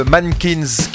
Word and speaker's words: MNNQNS: Un rock de très haut niveau, celui de Mankins, qui s'écrit pas comme ça se dit, qui s'écrit qu'avec les consoles, M-N-N-Q-N-S, Mankins,